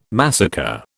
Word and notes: massacre: British English

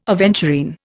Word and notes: aventurine: Indian

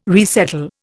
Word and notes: resettle: Indian

